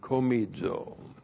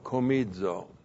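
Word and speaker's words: komizo (κομίζω): And then the verb komizo. K-O-M-I-Z, long O, komizo.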